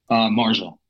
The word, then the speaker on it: Marjah: How do you pronounce it? – Marjah. – Oh, so it's the same as the other.